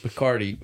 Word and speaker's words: Picardy: They said Picardy, which I love that as a word. Like it rhymes with Bacardi.